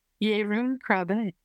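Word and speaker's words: Jeroen Krabbé: Yeroen Krabbe.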